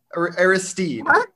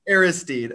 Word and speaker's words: Aristide: We'll start though with the news of the week, and that is Miami's second linebackers coaching hire of the offseason, Ishmael Aristidi. Aristide. We've gone over how to pronounce it. We've decided Aristide.